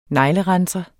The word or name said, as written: neglerenser